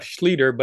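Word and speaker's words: Schlueter: Well, and in case you want to spell his last name, it's pronounced Seth Schlieder, but it's S-C-H-L-U-E-T-E-R.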